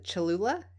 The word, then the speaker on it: Cholula: I have the Cholula.